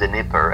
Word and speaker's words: Dnieper: It's Sacred Dnieper, and Dnieper, okay.